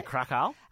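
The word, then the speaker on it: Kraków: Is that how you say it? Yeah, Krakow.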